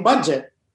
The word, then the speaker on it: Budjette: because my dad is Buddy, my mom is Adjet. So it became Budget. – Wow. – And that's why it's spelled with a J and a double T-E.